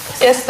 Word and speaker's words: Est: Est на этот раз произносится.